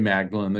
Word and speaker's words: Magdalen: I remember walking down a hallway at Magdalen College, because it's a collection of colleges, Oxford, and Magdalen, we would spell it like Magdalen, like Mary Magdalen. They call it Magdalen.